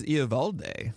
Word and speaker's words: Eovaldi: Yes, my comment is Eovaldi. – Eovaldi. That's how they pronounce it in his hometown.